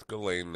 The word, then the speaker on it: Ghislaine: I know it's Ghislaine now. People have been very kind to correct me on the proper pronunciation of Jizzy's name, but I'm going to call her Jizzy from now on.